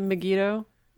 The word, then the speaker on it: Megiddo: Is it Megiddo? Is it M-E-G-I-D-D-O? Is that how it's spelled? – Yeah.